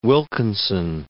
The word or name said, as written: Wilkinson